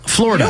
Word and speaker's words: Florida: It's pronounced two syllables. – Florida. – You don't pronounce the id.